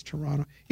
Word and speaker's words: Toronto: You don't pronounce the T. It's Toronto.